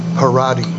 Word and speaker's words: Parady: The actress's name is Hersha Harady. I think that's how it's pronounced. – Yes. Sound right. – P-A-R-A-D-Y.